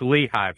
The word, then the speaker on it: Lehigh: I am well, and it's Lehigh Valley. – Lehigh? Wow. Okay. There you go.